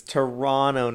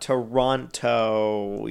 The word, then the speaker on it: Toronto: And it's Toronto, not Toronto.